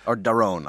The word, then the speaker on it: Daron: Which I can never pronounce his name, Damon Acemoglu. – Darren Acemoglu. – Yeah. And or Daron Acemoglu. – Daron, yeah.